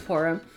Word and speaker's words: Purim: By the way, for those who celebrated, Blessed Purim, I always say that wrong. I always want to say Purim. That is Purim.